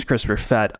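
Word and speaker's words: Christopher Perfett: My name is Christopher Fett. I am almost perfect. You can find me on Twitter at Christopher Fett, P-E-R-F-E-T-T is how you spell that last name.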